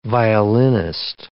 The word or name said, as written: violinist